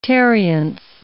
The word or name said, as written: tarriance